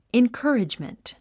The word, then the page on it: encouragement: inkə́ːridʒmənt; インカーリジメント